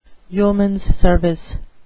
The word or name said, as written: yeomans service